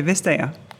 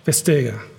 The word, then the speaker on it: Vestager: Maar haar naam spreek je net iets anders uit. We would say Margrethe Vestager. Vestager. Ja, yeah.